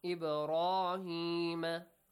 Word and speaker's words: إِبْرَاهِيمَ: إِبْرَاهِيمَ. Pronounce the letter ba with a qalqala, with an echoing sound.